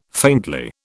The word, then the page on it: faintly: British English